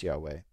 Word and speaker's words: Yahweh: The word Yahweh actually, and this is one of the reasons why we know it's pronounced Yahweh, or we believe very strongly that it's pronounced Yahweh, is because it's very similar to the Hebrew word for to be.